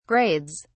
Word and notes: grades: British English

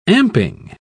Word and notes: amping: British English

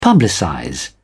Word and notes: publicize: British English

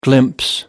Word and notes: glimpse: American English

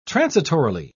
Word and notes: transitorily: British English

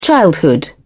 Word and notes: childhood: British English

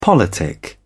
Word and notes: politic: British English

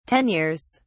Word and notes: tenures: British English

